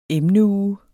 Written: emneuge

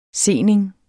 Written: sening

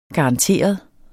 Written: garanteret

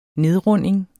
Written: nedrunding

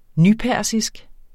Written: nypersisk

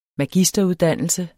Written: magisteruddannelse

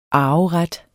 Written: arveret

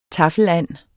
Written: taffeland

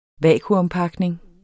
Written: vakuumpakning

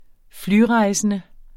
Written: flyrejsende

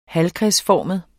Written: halvkredsformet

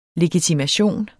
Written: LEGITIMATIONER

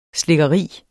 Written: SLIKKERIER

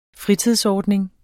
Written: fritidsordning

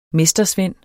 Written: mestersvend

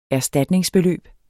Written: erstatningsbeløb

